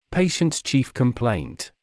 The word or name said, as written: patient’s chief complaint